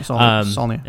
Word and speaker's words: Saulnier: Jeremy Saulnier. Is that how you say his name? – Yeah. Saulnier.